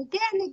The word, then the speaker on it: Agaain: The name is pronounced again. What kids say when they really like something. Again, again, mommy.